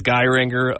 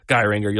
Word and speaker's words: Geiranger: It's tempting in the English to say it. Geiranger. That's kind of the Irish pronunciation. I think it's Geiranger.